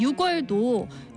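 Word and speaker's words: 유월: 예를 더 들어보면 6월도 6월도 6월이라고 하지 않고 6월이라고 하죠.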